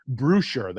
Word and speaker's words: Bewsher: So this gentleman, his name is Doug Brewsher. That's B-E-W-S-H-E-R. Did I say that right? Brewsher? – I think so. Brewsher? Is it B-E-R? – B-E-W-S-H-E-R.